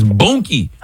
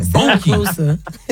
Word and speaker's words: Boonkie: No, it don't rhyme with Dookie. Bookie, and it'd be Bookie if it was Dookie. It's Boonkie. – Oh, right. – With an N, Boonkie.